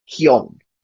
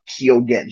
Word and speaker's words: Keoghan: Now, I've been trying to practice it today to make it sound right, and I might butcher it, but in Irish, how it would sound is Keown is how his last name, but he pronounces it in what how the English would pronounce it, more anglicized way of Keoghan. He pronounces the G in his name.